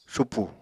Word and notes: supù: apatani

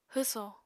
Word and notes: hwsò: apatani

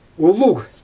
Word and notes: ulug: Xyzyl

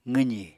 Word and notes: qwzì: apatani

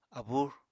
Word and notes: abúr: Apatani

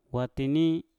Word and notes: watini: mpur

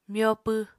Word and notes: myopw: Apatani

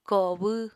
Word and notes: kobw: Apatani

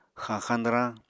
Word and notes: xañxañ-dwrañ: Apatani

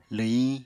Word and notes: lwhiñ: apatani

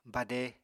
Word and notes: bade: Apatani

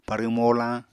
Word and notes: parw-molañ: Apatani

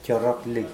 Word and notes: joaroakli: Mokilese

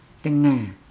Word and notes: dengaa: Nukuoro

